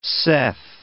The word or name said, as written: saith